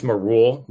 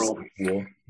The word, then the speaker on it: Marul: It's Marul, like Mars. Marul.